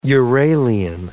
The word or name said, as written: Uralian